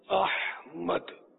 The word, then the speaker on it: احمد: دراصل وہ عربی تلفظ کے حساب سے تو احمد اور سحری یہ ہے اس کا تلفظ. ہا ساکن نہیں ہے, میرا خیال ہے مفتوح ہے, سحر سے ہوا نا سحر, وہ سحر تو جادو کو بولتے ہیں.